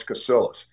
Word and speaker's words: Casillas: So everyone called me Casillas until I got to college, and I'm I finally had a hard, hard conversation. I said, Dad, I said, why, why is our name pronounced different than my other family? Because well, I just wanted to make it sound more Anglo.